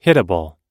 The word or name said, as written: hittable